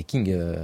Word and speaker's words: Qing: c'est les Qing, les Qing. Voilà, avec les Qing en prononciation lourde et française.